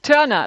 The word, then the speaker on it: Turner: Turner, like Tina Turner.